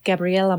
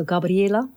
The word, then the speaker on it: Gabriela: Well, again, my name is Gabriela Martina, and Gabriela is spelled with one L. So Gabriela Martina, .com.